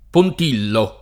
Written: Pontillo

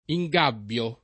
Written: ingabbiare

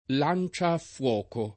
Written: lanciaffuoco

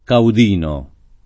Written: caudino

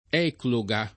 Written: ecloga

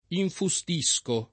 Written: infustire

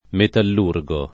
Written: metallurgo

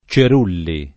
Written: Cerulli

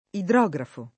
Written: idrografo

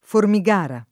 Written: Formigara

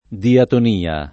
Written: diatonia

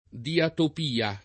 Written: diatopia

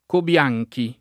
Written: Cobianchi

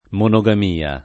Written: monogamia

